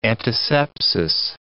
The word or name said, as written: antisepsis